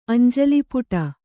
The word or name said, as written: ಅಂಜಲಿಪುಟ